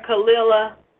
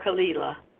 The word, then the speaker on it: Khalilah: Khalilah or Khalilah? – No, or does it matter? – Khalilah is fine, because I have Lila. A-H, Lila. So, Khalilah. People from Philadelphia call Khalilah. So, people from Philadelphia call me Khalilah.